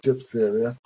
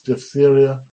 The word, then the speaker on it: diphtheria: So in those examples we have there, we have diphtheria, don't say diphtheria. It's diphtheria, emphysema, muscular dystrophy, I can't say it properly, muscular dystrophy.